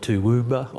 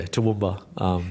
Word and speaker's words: Toowoomba: People call it Toowoomba or Toowoomba. It rolls off the tongue pretty easily for us locally, but yeah, I've heard some different ways that people tried to pronounce it or say what it.